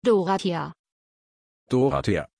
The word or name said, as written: Dorathea